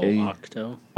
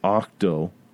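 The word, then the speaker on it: Ahch-To: The couple things that's in here is, I don't know, how do you say it? – Octo. – Octo. Yeah.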